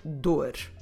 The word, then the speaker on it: dor: So first of all, the word for ache or pain in Portuguese is the word dor. A dor. Como se escreve dor? D-O-R.